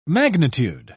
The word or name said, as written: magnitude